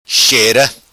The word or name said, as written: Xera